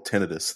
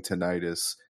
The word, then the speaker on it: tinnitus: Yeah, about the whole tinnitus thing, by the way. I was trying to convince these guys for like a solid hour that they're saying tinnitus, but the proper pronunciation is tinnitus.